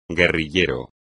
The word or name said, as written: guerrillero